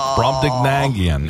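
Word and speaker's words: Brobdingnagian: Brobdignagian. Brobdignagian is the word. – One silent G and one non-silent G.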